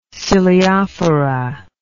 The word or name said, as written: ciliophora